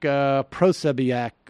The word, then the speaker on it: Posobiec: Nevertheless, he was fired by Marvel and Disney when Mike Cernovich and Jack Posobiec, what? How do you pronounce? How do you? – Posobiec.